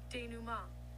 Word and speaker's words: denouement: G-E-N-O-U-E. Oh my gosh, this word is just confusing. O-U-E-M-E-N-T. Okay. Let's hear it. – Denouement. – Denouement. – Oh. – Denouement. – Denouement. Denouement. Okay, so it's denouement. Darn it, we've been saying it wrong the whole time.